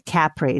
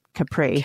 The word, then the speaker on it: Capri: Yeah, they pronounce it Capri, not Capri.